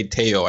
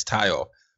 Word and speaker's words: Tayo: And I was like, and I should have thought of it because people always mispronounce my first name. They say Teo and it's Tayo.